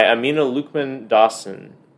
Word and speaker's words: Amina Luqman-Dawson: But the 2023 Newbery medal winner is called Freewater by Amina Lukman Dawson. Is that how you pronounce her name? – That's how I pronounce it. – All right. I thought so.